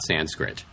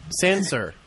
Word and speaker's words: Sansa: Her name is not Sanskrit. – Sansa. – It's Sansa or Sansa. S-A-N-S-A. There's no R at the end.